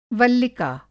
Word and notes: वल्लिका: Hindi

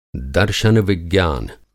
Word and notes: दर्शनविज्ञान: Hindi

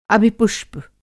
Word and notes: अभिपुष्प: Hindi

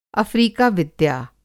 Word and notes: अफ़्रिका विद्या: Hindi

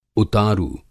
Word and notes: उतारू: Hindi

